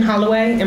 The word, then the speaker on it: Holloway: My name is Kristian Holloway, and my last name is spelled H-O-L-L-O-W-A-Y.